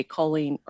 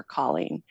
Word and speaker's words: Colleen: And I'm like, sure, it's Colleen. And I actually think I prefer the pronunciation Colleen a little bit more.